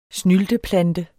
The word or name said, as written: snylteplante